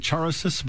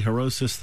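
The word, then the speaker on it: Charoset: Now, it looks like Charosis, but it's really Herosis. The C in Hebrew is silent, so Herosis.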